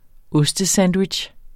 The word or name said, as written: OSTESANDWICH